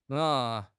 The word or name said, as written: nei3